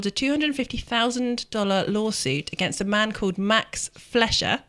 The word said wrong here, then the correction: Fleischer: I may not be pronouncing his name correctly. Flesher? – Is there an I? – F-L-E-I-S-C-H-E-R. – Flesher. Yes, this Fleischer.